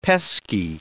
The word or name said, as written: pesky